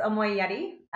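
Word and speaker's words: Omoiyari: Omoyari. – Yeah. – Is that how you pronounce it? – Yes, Omoyari, which is about the art of compassion.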